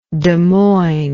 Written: Desmoinesian

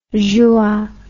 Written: juin

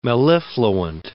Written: mellifluent

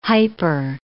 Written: hyper

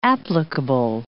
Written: applicable

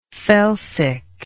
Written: felsic

